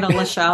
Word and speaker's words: Alisha: I definitely, a lot of people say it wrong. I get Alisha a lot, but, right.